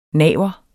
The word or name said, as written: naver